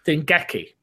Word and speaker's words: Dengeki: I'm going to butcher this gentleman's name, but Dengeki, I think that's right. Dengeki. The Jenki? – Dengeki. – That makes more sense.